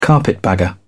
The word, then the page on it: carpetbagger: British English